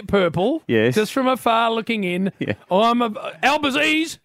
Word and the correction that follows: Albanese: Old Albo, in he comes. – Albanese. – Albazese, and in he comes. And I tell you, he's getting the name wrong.